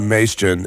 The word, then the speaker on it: Majcen: It's Leon Maestron. It's spelled M-A-J-C-E-N.